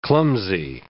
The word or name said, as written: clumsy